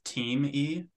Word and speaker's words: Thieme: So it was like, Theme, time, timey, and then teamy, which it actually is, like team-e. So that's why some of my branding is the A-team, Alex Thieme, the A-team.